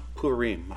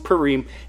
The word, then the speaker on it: Purim: The book's primary theme is to record the institution of Purim, and it's pronounced Purim. I actually looked it up. It's pronounced Purim, and it's an annual festival usually taking place in the months, our months, of February and March.